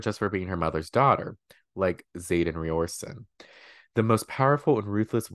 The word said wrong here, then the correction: Riorson: wing leader, I probably said Riorson wrong. How do you say it? – Ryerson! – Xaden Ryerson.